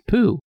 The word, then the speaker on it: Pou: P-O-U, but it's pronounced Pooh.